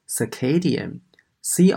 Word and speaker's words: circadian: 好，现在呢，我们来看一下第二个单词，它呢是一个形容词，读作 circadian，c i r c a d。